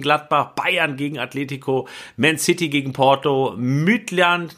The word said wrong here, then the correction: Midtjylland: nein, wie wird das richtig ausgesprochen, Bennett? Midtjylland, ne? – Ja.